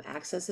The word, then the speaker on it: AXS: And this is on what's the network? Access? Is that how you Yes. – pronounce it? – AXS, Access TV, AXS TV. And yeah, it's like good old fashioned cable.